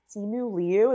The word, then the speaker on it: Simu Liu: I thought Simu Liu, is that how you pronounce yeah.